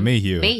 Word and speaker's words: Mayhew: So we get introduced to the main character, Richard Mayhew. Is that how you pronounce his name? – Yeah, Mayhew.